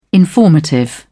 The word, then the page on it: informative: British English